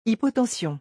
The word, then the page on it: hypotension: French